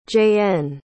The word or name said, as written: jaen